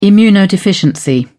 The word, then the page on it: immunodeficiency: British English